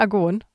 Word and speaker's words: Argon: Argon oder Argon? Also ich habe extra, wir gehören ja ne, zum SWR und zur ARD, in dieser tollen ARD-Aussprachedatenbank nachgeguckt und der Typ sagt sowohl Argon als auch Argon.